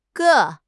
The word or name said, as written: ge4